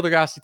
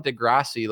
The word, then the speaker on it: deGrasse: I'll let you're Neil deGrasse Tyson. I'll let you talk. Crazy, crazy stuff. DeGrasse, the E isn't pronounced.